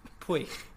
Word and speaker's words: Puig: What did you call him? – Puig. French. – First of all, that's correct pronunciation.